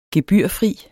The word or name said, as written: gebyrfri